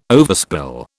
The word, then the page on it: overspill: British English